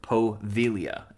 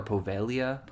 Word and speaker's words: Poveglia: So we're going to be looking at a tiny island by the name of Poveglia, and that is spelled P-O-V-E-G-L-I-A. So it looks like Poveglia, Poveglia. – For us Americans. – Yeah, but it's Poveglia? – Yeah, Poveglia or Povelia. – Povelia? – Might be Povelia.